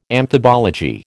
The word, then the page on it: amphibology: American English